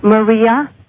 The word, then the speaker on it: Maria: Maria. – How do you spell it? – L-I? – M-A-R-I-A. M-I-R-R-A? – M-A.